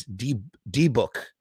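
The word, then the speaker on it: dybbuk: So Toxic Swamp actually says the rabbi in the original film from 1907 pronounced it as D-book.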